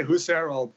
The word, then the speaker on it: Husserl: I always say Husserl.